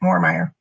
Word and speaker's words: Moormeier: Yes, that's correct, Moormeier.